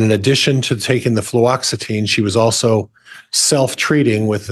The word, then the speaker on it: Fluoxetine: I have difficulty with that as well. Fluoxetine. – Fluoxetine. Thank you.